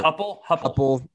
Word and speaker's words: Heupel: I don't know how you say his name, but he was former Heupel.